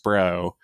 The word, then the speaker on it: SPRO: So, I mean, the one thing they can do is release everything in the SPRO, in the Strategic Petroleum Reserve, which is ridiculously pronounced SPRO.